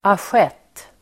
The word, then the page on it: assiett: [asj'et:]